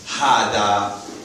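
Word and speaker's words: hada (هذا): Hada. Hada means... Hada means this. Technically, hada is pronounced like this. Ha and the. It's hada. It's as if there's a alif there.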